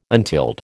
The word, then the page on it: untilled: American English